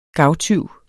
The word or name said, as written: gavtyv